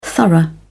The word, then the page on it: thorough: British English